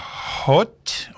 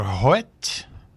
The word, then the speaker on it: Emma Hoet: That was Emma Hoet, or Hoet. That's how her name is spelled, H-O-E-T.